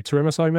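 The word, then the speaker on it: Tsurumso: It's spelled T-S-U-R. – Yes. – Yeah. U-M-S-O. Turismo. Turismo, maybe? – There we go.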